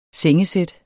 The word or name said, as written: sengesæt